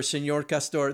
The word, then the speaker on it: Señor Castor: Well, I have to say, I mean, I love Mr. Beaver, Señor Castor.